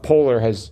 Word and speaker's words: polar: Polar is two syllables, right? – Oh, yeah.